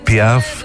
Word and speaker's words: Piaf: Αυτό το R της Piaf δεν υπάρχει.